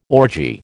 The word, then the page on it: orgy: American English